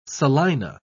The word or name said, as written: salina